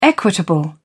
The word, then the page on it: equitable: British English